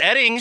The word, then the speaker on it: Oetting: Mark Oetting. – Hold on. It's pronounced Edding. – But it's spelled O-E-T-T-I-N-G. – Yes, and O-E-T-T-I-N-G.